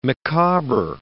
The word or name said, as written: Micawber